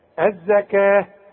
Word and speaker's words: الزكاة: الزكاة، كل الزكاة بتاعة القرآن مكتوبة بالواو لكن تنطق بالالف، الزكاة، ورسم لك الفي، ثم توليتم.